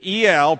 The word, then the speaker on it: El: The E-L, pronounced El in Hebrew, is the name of God.